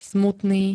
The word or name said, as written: smutný